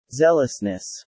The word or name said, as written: zealousness